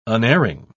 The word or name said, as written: unerring